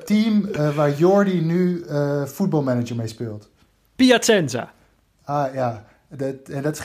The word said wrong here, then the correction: Piacenza: Maar hoe spreek je het uit dan? – Piacenza. – Piacenza, oh ja, nou ja.